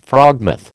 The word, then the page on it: frogmouth: American English